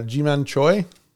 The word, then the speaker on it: Jiman Choi: Another future Brewers player, Jiman Choi. Mm-hmm. Pronouncing that rightly? Is that right? – Oh, yes. Yes, absolutely.